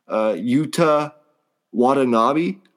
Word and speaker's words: Yuta Watanabe: Yuta Watanabe, I believe is how you say his name.